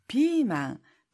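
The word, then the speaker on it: 피망 (ピーマン): 비망, 피망, 피망인데요.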